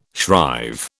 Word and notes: shrive: British English

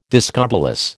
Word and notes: discobolus: American English

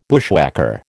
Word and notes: bushwhacker: American English

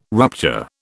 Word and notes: rupture: British English

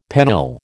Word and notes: pennill: American English

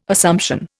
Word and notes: Assumption: Indian

